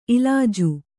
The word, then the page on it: ಇಲ್ಲಾಜಿ: illāji